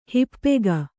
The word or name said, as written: ಹಿಪ್ಪೆಗ